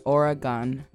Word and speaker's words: Oregon: It's pronounced as if you were to spell it O R Y G U N, Oregon.